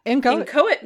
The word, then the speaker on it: inchoate: Yes, and it's spelled, it's such a weird word, I-N-C-H-O-A-T-E, but it is pronounced inchoate. – It looks like it should be inchoate. – I know, but it's not.